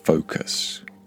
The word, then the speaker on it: focus: The first vowel sound is a long O sound. You get that in words like no and so. So we would say focus, not focus. The second vowel sound is almost non existent. It is a vowel sound called the schwa by linguists and language teachers, and the schwa sounds like this.